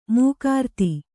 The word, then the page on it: ಮುಖರಿತ: mukharita